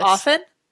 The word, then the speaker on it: often: I think if you pronounce the T in often, you're a fucking nerd.